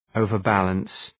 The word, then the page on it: overbalance: {‘əʋvər,bæləns}